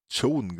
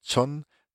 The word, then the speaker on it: Joan: Und ich habe nicht wieder schlecht Englisch ausgesprochen, also vielleicht doch, aber auf jeden Fall habe ich absichtlich ähm, Joan gesagt und nicht John,